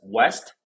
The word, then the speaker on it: W3ST: So first of all, it's pronounced as West. – West. Yep.